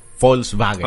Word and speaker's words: Volkswagen: la W se supone que se pronuncia como B. exactamente, Volkswagen. – Claro, Volkswagen. Porque la B corta se pronuncia como F. Como F. Entonces sería Volkswagen.